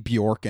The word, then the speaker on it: Björk: Now, before we go any further, just know that her name is properly pronounced Björk. Even I'm used to saying Björk, and Björk is definitely, at least people in America, what she's better known as, but that's not her name.